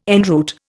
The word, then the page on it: en route: Indian